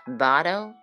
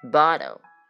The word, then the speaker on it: bottle: 这个 bottle 这个 double d 呃 double double t 的发音呢是类似于 d 的音，但是有一点弹舌的感觉。 bottle bottle 药量信息呢叫 dosage information。